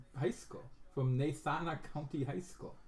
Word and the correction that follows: Natrona: Natrona. – Well, all right. – Here's Wyoming. – Which rhymes with Corona.